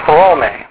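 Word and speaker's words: phrome (Ῥώμη): Actually, it's phrome. Phrome. You got to say the first syllable accent, and it's two syllables.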